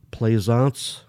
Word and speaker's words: Plaisance: You can follow me by following my name, which is Michael Plaisance on Facebook. That's spelled P as in Paul, L-A-I, S as in Sam, A-N-C-E.